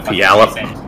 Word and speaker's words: Puyallup: Puyallup.